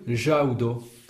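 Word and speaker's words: ĵaŭdo: Il faut qu'il y ait un accent sur le U pour prononcer Jaudo. S'il n'y en avait pas, on prononcerait Jaudo. – Ah oui. – Avec un U long. Ouais, tandis que là, c'est le U accent, c'est un U. Le son ou, mais qui est court.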